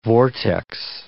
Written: vortex

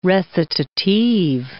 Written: recitative

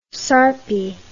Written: sarpi

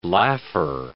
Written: laugher

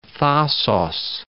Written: Thasos

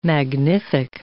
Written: magnific

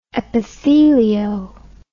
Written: epithelio

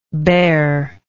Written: bareness

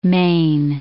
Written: Maine

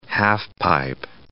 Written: half-pipe

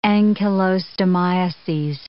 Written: ancylostomiases

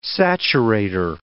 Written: saturator

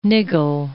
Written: niggle

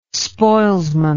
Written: spoilsman

